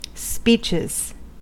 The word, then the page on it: speeches: American English